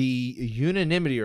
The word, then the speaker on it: unanimity: The unanimity report for this one. I said that right again. I've been better ever since I bottomed out, right? – I think ever since when I wrote, I broke it down in syllables for you.